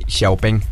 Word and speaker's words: Xiaopeng: De hecho, en China lo pronuncian así, Xiaopeng.